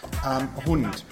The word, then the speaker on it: Hund: Hund. – So it's pronounced hund, yeah? – Very good, yes.